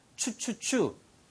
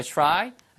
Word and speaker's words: try: 이거 발음이 try라고 안 들리고요. 추추추. 추추. 이게 기차 갈 때라는 소리. try처럼 들립니다. Let's give it a try.